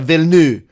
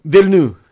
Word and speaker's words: Villeneuve: It's like Villeneuve. It's like Villeneuve or something like that. It's we're really wrong. Like, we're not even close. – So, like, there's a missing syllable. It's just like Villeneuve. – Villeneuve.